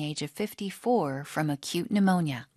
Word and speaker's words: pneumonia: pneumonia 앞에 P는 묵음입니다. 그래서 pneumonia, 급성 폐렴 증상으로 갑작스럽게 세상을 등지셨다.